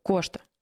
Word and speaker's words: Кошта: Среди них это глава Евросовета Антонио, я вот не знаю, как правильно ударение. – Кошта.